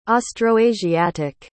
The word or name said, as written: austroasiatic